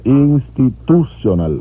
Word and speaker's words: Institutional: Institutional, el acento va en la U.